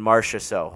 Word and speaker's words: Marchessault: They're getting timely scoring from guys like Eichel, Jonathan Marchessault.